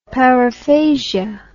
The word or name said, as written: paraphasia